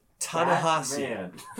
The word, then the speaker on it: Ta-Nehisi: I'm reading that it's pronounced Ta-Nehisi. Ta-Nehisi Coates.